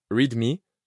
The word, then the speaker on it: README: Un berisson, que l'on prononce « readme », soit « lis-moi » en français,